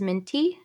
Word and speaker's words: Mintea: Hello, my name is Abby, and my artist name is Mintea.